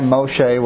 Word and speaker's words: Moshe: Which were given by God to Noah as a binding set of laws for all humankind and were revealed by Moshe, which that's Hebrew for Moses. That's how they pronounce it.